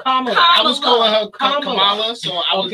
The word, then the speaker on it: Kamala: Oh, Camilla? – I can't say her name, so I shouldn't. – Kamala.